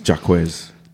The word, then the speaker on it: Jaquez: Jaime Jacques Jr. Did I say it right? Jaime Jacques? – Jacques. – No, he's not. That sounds French. – It's Jaquez. – Or it's Jaquez. His name is Jaime Jaquez Jr. Haktua.